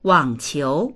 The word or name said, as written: wang3qiu2